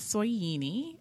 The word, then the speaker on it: Soyini: And spelling of my name is Soyini, S-O-Y-I-N-I, and my last name is Coke, like the drink.